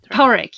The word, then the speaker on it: Padraic: Is it Padraic? I don't know how to pronounce it. – And he's Porrick, yes. Mm. Written with a D, but yeah.